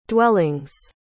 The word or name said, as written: dwellings